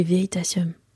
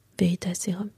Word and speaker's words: Veritasium: C'est Veritasium. Veritaserum.